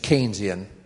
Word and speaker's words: Keynesian: Well, I knew I was in for trouble because the proper pronunciation is Keynesian, and it was almost always from somebody who thought that Keynes was was the handmaiden, of the devil.